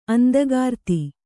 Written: ಅಂದಗಾರ್ತಿ

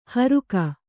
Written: ಹೋರ್ಕೆ

